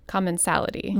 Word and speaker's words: commensality: The fourth chapter in Recipes for Respect is titled Civil Rights and Commensality.